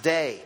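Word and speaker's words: day (δεῖ): It's the Greek word DEI. It's pronounced day.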